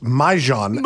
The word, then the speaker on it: Mahjong: It's, by the way, it's properly pronounced Mahjong.